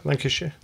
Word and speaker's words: Lancashire: Wealthy businessman Joel Tobias, 52, had been flying his wife and family to the Lancashire, did I say that right? – Yeah, Lancashire.